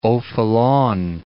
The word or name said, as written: O'Faolain